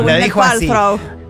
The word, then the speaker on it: Gwyneth Paltrow: Muy bien pronunciado, Gwyneth Paltrow.